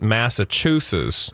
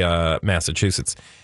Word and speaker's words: Massachusetts: We're going where dumb people pronounce it Massachusetts. – Mm. – But it's really Massachusetts.